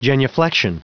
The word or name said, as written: genuflection